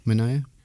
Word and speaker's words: Manaea: Sean Manaea. Am I saying his name correctly? – I think so. Manaea.